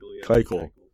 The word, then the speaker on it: Keuchel: Keuchel.